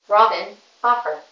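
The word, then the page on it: Robyn Hoffer: English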